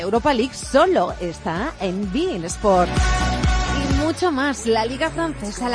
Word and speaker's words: beIN Sports: Sí, se escribe beIN Sports, pero se pronuncia beIN Sports.